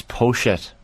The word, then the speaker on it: Poyet: It's Poshet. Poshet? Poshet? Y is S-H in Uruguayan. It's not French, so pronounce the T at the end. So that would be Poshet.